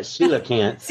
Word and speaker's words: Coelacanth: And one of them was, I'm going to say this wrong, is it Sheila Kent? – Oh, you got it. Coelacanth.